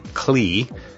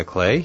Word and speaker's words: Klee: And by the way, if you were a geeky engineering student like me, you may be pronouncing his name Klee, K-L-E-E, just as it's spelled. However, I have it on very good authority that Klee is a proper pronunciation.